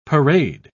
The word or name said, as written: parade